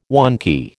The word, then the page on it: wonky: American English